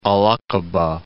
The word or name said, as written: Al-'Aqabah